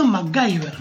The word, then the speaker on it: MacGyver: Nosotros le decimos MacGyver. Se pronuncia MacGyver.